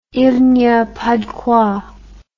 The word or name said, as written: il ny a pas de quoi